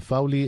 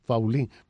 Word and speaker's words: Faulí: tú dices que Fauli es un apellido catalán y que no se pronuncia Fauli, sino Faulí, pero estamos acostumbrados a llamarte Valdés Fauli.